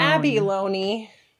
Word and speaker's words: Abalone: It's not Abby, it's Abalone. – It's Ab is alone. – Abalone.